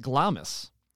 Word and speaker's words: Glamis: The resident historian, she prefers to pronounce it Glamis, like the Scottish castle in Macbeth it was named for.